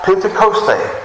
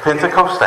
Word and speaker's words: Pentecoste: It's derived from a Greek word that is called Pentecoste. Y'all hear that? Pentecoste. I pronounced that one right because I listened to it. Y'all know I sometimes butcher the words. So that one is right.